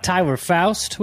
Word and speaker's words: Tyler Foust: Today we have Tyler Foust with us.